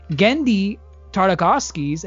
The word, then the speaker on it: Genndy Tartakovsky: Maybe not everyone in our audience is familiar with it, but Genndy Tartakovsky's, if I butchered that name, don't kill me. – No, you did. That's it. You did it.